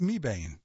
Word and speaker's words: Mebane: Next in Alamance County, we have Mebane, which is an interesting word because I know somebody whose name is spelled that way and she pronounces it Mebane.